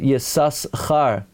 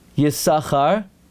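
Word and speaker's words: Yisachar (יששכר): And although the Chassam Sofer held in his parish Al HaTorah, the first time you pronounce it Yisus Char, to show that's his name, and after that Yisachar, the Mincha Shai says you should say Yisachar every time.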